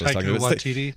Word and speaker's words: Taika Waititi: I'm going to mispronounce it because it's Taika Waititi. Is that right? – Thor Waititi. Ragnarok director. Taika. Maybe it was Brian or somebody else. – Taika Waititi.